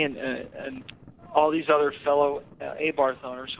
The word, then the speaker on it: Abarth: An expert told me how to pronounce it. It's called Abarth.